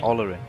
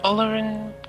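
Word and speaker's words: Olórin: Olorin. – Olorin was his name, but of Olorin we shall never know more than he revealed in Gandalf.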